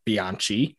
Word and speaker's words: Bianchi: I'm already very clearly not a pure Italian because I pronounce my name Bianchi, uh, but I'm not pasta is you know, take it or leave it.